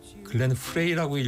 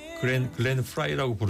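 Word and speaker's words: Frey: 글렌 프라이가 F-R-E-Y이기 때문에 글렌 프레이라고 읽어야 되나 뭐 좀 고민했는데 어느 다큐멘터리 보니까 돈 헨리가 글렌 프라이라고 부르더군요.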